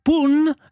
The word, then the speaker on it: La Punt: Moi, j'ai appris comme parole, je savais pas qu'on dit, on dit pas le pont, on dit la, donc c'est féminin, Pun, alors qu'il y a un T à la fin, mais on le prononce pas, la Pun.